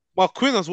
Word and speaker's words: Marquinhos: Marquisios. How'd you say his name again? – Marquinhos, what, from PSG? – Yeah, PSG, that's it.